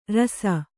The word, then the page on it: ರುಸಿ: rusi